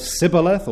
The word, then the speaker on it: Shibboleth: And you might recall how that the Ephraimites had a particular way of pronouncing the word Sibboleth or Shibboleth, the S and SH sounds being almost interchangeable in the Semitic or Shemitic languages.